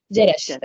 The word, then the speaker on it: Jerash: Jerash.